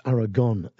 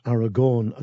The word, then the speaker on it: Aragon: I don't know if you're listening, lads, but it's Aragorn, A-R-A-G-O-N, not Aragorn as it's spelt on your CD, A-R-A-G-O-R-N.